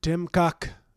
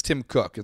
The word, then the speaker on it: Tim Cook: Tim Cuck. – But it's Tim Cook. It's not Tim Cuck.